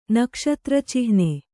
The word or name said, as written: ನಕ್ಷತ್ರಚಿಹ್ನೆ